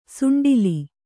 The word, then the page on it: ಸುಂಡಾಲ: suṇḍālu